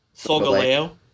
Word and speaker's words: Solgaleo: Solgaleo.